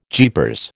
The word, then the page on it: jeepers: American English